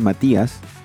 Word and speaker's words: Matías: Matthias, dicen ellos. Yo digo, es Matías, es latino, pero no importa.